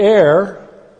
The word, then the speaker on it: heir: I didn't pronounce that right. I almost said error. "The heir,